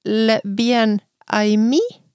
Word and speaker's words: Le bien aimé: Nei, det var en lytter som mente at eh, det skal du si le bien aimé. Oh. Med i på slutten.